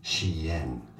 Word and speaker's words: Shein: Why does Google tell me it's pronounced shine? How to say Shein? Let's see. – This fashion and clothing brand name is pronounced as Shein. – I see. Shein. I said it right.